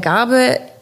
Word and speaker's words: Garbe: Leiter des Bereichs Business Development bei der Gabe Industrial Real Estate GmbH.